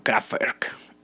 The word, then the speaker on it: Kraftwerk: en alemán sería como Kraftwerk, pero yo les digo Kraftwerk, ¿no?